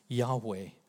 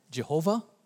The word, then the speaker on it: Yahweh: Yahweh. It's also, you may have heard the word Jehovah. That's actually a mispronunciation, a rather gross mispronunciation, but let's not worry about that.